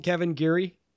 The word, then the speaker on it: Kevin Geary: And is that how you pronounce it, Kevin Geary? – Yep, that's okay, right.